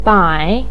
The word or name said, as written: bai3